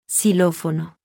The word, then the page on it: x: Spanish